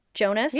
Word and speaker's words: Jonas: And the way we find this out with finality is that Jonah's dad, am I saying, it's Jonah, right? Jonas? – Yeah, it's Jonas. – Jonas. – There's an S, okay.